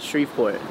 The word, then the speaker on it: Shreveport: So you were born in, how do you say it, Shreveport? Or? – Yes, Shreveport, yeah. Louisiana.